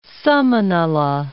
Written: Samanala

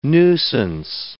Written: nuisance

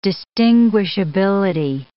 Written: distinguishability

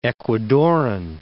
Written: Ecuadoran

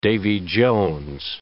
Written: Davy Jones

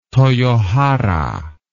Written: toyohara

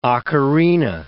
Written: ocarina

